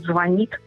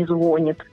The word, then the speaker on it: звонит: Только ленивый не говорит о том, что нужно говорить «звонит», а не «звонит».